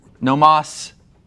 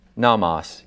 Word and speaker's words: nomos: Is that a long O or two shorts? – Two shorts is the same bet. – Yeah, okay. Nomos is the word for law.